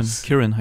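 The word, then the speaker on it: Ciarán: Ciaran heißt er.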